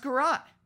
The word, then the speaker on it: garrote: And, you know, you just, okay, Garrote, it looks and sounds right. Apparently it's pronounced Garrote. – You are the smartest of the three, yes, you have it correct. Merriam-Webster confirms.